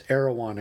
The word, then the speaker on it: Erewhon: It's nowhere spelled backwards, by the way. It's Erewhon, I think, is the way it's pronounced.